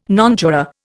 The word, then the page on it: nonjuror: Indian